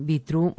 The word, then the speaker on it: Betrue: Betrue, se escribe así, Betrue, mujer. Seguramente que van a escuchar decir Bitru, mujer.